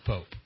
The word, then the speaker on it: Poppe: Her name is Patty Pope, spelled P-O-P-P-E. You could pronounce that another way if you like, but I'll go with Pope.